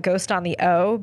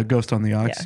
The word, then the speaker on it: Ghost on the Ox: It doesn't roll, like, at first, and you kind of want to call it Ghost on the O because of the way that, it, like, the like, it's just, it's spelled in a weird way that, like, at first you're not even sure what it means on the end, like, how to even pronounce it.